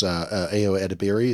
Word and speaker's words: Edebiri: I love the actress, Ayo Edebiri. Is that how you say her name? – Yep.